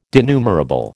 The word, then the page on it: denumerable: American English